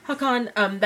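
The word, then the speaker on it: Håkan: But then Hakan... I don't know how to say it. – Hakan.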